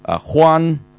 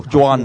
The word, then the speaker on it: Juan: 咁佢由 1946 呢就一路就去到 1955 年即係執政咗 9 年呢，佢就叫咩呢，就叫做 Juan Perón。咁呢、這個 Juan 咧，Juan 咧，真系我都即系个发音都要小心啊，因为佢系 Juan 嘅吓，Juan 咧，你又唔好读 John，佢唔系 J 字音嘅，其实就系我哋嗰个剑侠唐王啊，嗰、那个唐王咧就 Don Juan 啊，